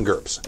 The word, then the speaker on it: GURPS: If I do it, I would do that, definitely do that in GURPS. – Yeah. – I'm sorry, you're saying it wrong. GURPS, Yeah! There you go.